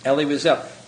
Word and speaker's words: Elie Wiesel: Elie Wiesel.